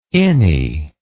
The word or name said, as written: innie